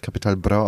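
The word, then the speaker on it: Capital Bra: Ja, es wird Kapital Bra ausgesprochen, aber ist auch nicht so schlimm.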